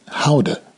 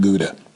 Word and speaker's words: Gouda: The locals call it Houda, not Gouda.